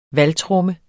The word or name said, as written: valgtromme